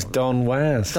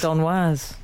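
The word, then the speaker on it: Don Was: Was so called because Don's surname is pronounced to rhyme with jazz. – Oh, so his name was Don Waz. – Don Waz.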